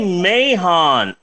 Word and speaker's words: Mahon: One of the leading directors in the world of nudie cuties was a guy by the name of Barry Mahon. I'm pretty sure that the H is silent, and his actual name is Barry Mann, but I believe that all letters matter.